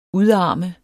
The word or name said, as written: udarme